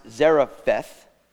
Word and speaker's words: Zarephath: And the Lord brings him to a place in English, I think it's pronounced Zarephath. In Hebrew, it's Seraphet. Seraphet Sidon is where he is going to go.